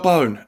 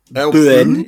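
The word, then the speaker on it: Boen: Yeah, Earl Bone. I mean, unless I'm pronouncing it incorrectly, because it's B-O-E-N. – Boone, yeah. – Boone?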